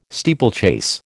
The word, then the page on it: steeplechase: American English